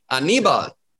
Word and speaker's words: Aníbal: I was like, where is the emphasis? It's on the knee, Aníbal.